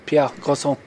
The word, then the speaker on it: Pierre Garçon: How do you say that? – Pierre Garçon.